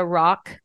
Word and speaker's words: Rocque: Another place that you can find me or this freebie, I'm on both Instagram and Facebook at Julia Rock Coaching, but my last name is spelled tricky.